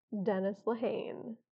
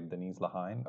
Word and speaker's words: Dennis Lehane: about a month ago, we finished Gone, Baby Gone by, Dennis Lehane. – and I'm asking her to say it because I don't know how to pronounce his last name, so I was probably going to say Denise Lehane.